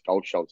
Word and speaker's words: Kaohsiung: And, they pronounced the K with the G, so it's Kaohsiung.